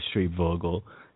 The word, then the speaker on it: Schreibvogel: Yes, I like how you pronounce his last name. Very good. – I think it's it. Schreibvogel or Schreibvogel. – Tomato, tomato, but I'm glad you didn't stumble all over it.